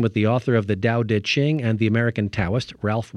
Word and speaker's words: Tao Te Ching: The ancient text is the Tao Te Ching, yes, spelled Tao, but pronounced with D sounds.